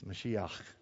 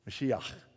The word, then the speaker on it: Mashiach: And I have a sweet friend who's taught me how to say it in Hebrew. It's Mashiach. You get it? Did I get it? Mashiach.